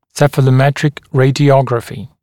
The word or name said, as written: cephalometric radiography